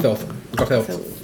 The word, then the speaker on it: Gothelf: At J Boogie, who's Jeff Goff. I'm going to say it wrong. – Gothelf. – Thank you.